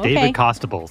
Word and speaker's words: Costabile: I have a pronunciation key, and it says David Costabile.